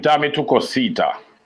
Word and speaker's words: Dame Tu Cosita: The song name, Dame Tu Cosita.